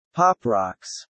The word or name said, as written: pop rocks